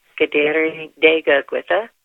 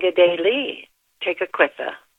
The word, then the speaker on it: Kateri Tekakwitha: There are several pronunciations of her name from the Mohawk language, Kateri Tekakwitha, and Gedeli Tekakwitha.